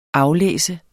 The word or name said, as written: aflæser